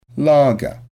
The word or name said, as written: lager